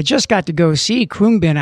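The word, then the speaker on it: Khruangbin: It's K-H-R-U-A-N-G-B-I-N, pronounced Kroonbin.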